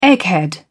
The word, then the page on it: egghead: British English